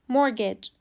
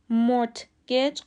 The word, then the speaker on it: mortgage: تلفظ درست مورگج مورتگج غلطه چون تی سایلنت و